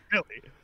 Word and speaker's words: Millie: Millie or Melly? – Mel. Oh, well, how are you to pronounce it? – Woo! Well, it's Millie.